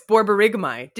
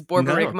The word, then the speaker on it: borborygmi: And then, do you know how to pronounce this? Oh, it's borborygmi. Did you know that's how you say it? Like the intestinal like gurgling is borborygmi? No. Have you ever seen that before? Something that's such a cool word. I had to look up on Google how to say it.